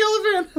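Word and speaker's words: Olyphant: Luke Wilson, Portia de Rossi, Timothy Oliphant. – It's Timothy Oliphant.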